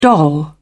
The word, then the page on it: doll: British English